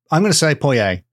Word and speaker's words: Poyais: According to Lippincott's Pronouncing Gazetteer, 1856, it's pronounced something like Poyais. I'm going to say Poyais because that's how I would pronounce it.